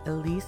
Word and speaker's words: Elysse: It's the That right there was my friend Elise with her song Eve, E-V-E. You pronounce her name Elise, you spell it E-L-Y-S-S-E.